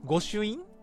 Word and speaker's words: 御朱印: 寺院や、神宮のこれは何だろう、御朱印で合ってるのかな、読み方。 – うん、合ってます、はい、合ってますかね。